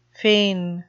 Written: fane

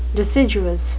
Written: deciduous

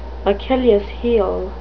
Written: achilles heel

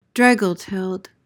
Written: draggletailed

